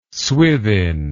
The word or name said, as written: swithin